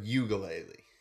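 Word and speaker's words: Uiagalelei: I don't want to say his name wrong, but I think it's Ugalele. – Yeah. – Okay. Because I try to remind myself it's like ukulele. But it's ukulele, but ugalele.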